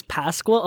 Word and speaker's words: Pascua: Of, it is Pascua. A lot of people pronounce Pascua. It like they think it's a Q.